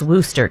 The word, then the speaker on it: Worcester: Worcester. Worcester. That's what apparently the British say it's just Worcester because it's named after a town or a city or a village in the UK.